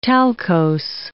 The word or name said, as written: talcose